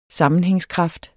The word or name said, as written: sammenhængskraft